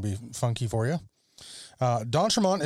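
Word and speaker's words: d'Entremont: So, you know, go on Instagram, follow Don Tremont Guitars.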